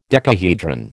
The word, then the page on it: decahedron: American English